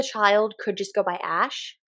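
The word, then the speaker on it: æ: Oh, I literally spelled Ash, which is not correct. A-Ash... Okay, so the A-E symbol thing is pronounced ash.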